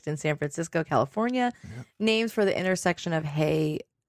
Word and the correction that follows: Haight: Is it Haight? – How do you pronounce it? Just Haight and Ashbury.